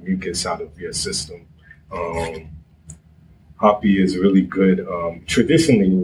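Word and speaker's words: rapé: And with it's usually R-A-P-E with a little apostrophe thing on there, no Rapi. But some people call it, it's usually pronounced as Hoppy.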